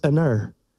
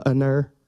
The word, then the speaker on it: Aner: And I actually looked that up. I thought, surely I'm not saying that right, but I am. It's Aner. So like when somebody come and ask you if you want to go for a run this afternoon, you can go, Aner.